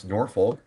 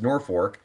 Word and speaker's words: Norfolk: It looks like it should be pronounced Norfolk, but it is pronounced Norfolk.